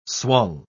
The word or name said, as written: swung